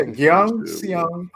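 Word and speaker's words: Gyeongseong: There's a show called Young Siang Creature. I forgot how to pronounce it. – Oh yeah, Young. – I think I something saw that wrong. – Something. Let me look it up real quick. I'm butchering the shit out of that name. I'm sorry. Hold on. Yeah, Gyeong Seong Creature. Yeah, that's how you say it.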